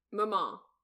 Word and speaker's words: Maman: Am I saying that right? – Maman. Like. – Maman. – Yeah, like, so you don't M-A-M-A. Pronounce the N. – Yeah, it's French. You don't pronounce the last letter.